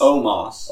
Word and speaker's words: Omos: Almas. Almas. – Oh. – Almas? – Omos. – Omos. Omos. I don't know. – Almost. – O-M-O-S is how you spell it.